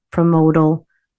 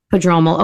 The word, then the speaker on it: prodromal: So I'm like, okay, this is promodal labor. I think I said that right. – Prodromal.